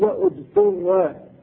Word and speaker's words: اضطر: قال الله تعالى فمن اضطر، فمن اضطر، اذا ابتدئ بالفعل قيل اضطر بضم الهمزة والطاء.